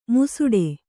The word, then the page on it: ಮುಸುಡೆ: Kannada